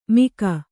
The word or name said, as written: ಮಿಗ